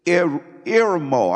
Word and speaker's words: eremo: In the Greek, it's eremo. I'm going to spell it to you. E-R-E-M-O-O. Eremo. Eremo.